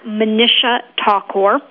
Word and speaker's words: Manisha Thakor: It's at Manisha Thakur. And I will spell it M-A-N-I-S-H-A-T-H-A-K-O-R.